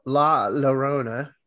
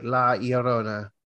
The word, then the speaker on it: La Llorona: So being a heathen that I am, uh, it's La Llorona. However, I think it's more like La Llorona. Yeah, in Spanish, when you see the double L's, it's always pronounced like a Y.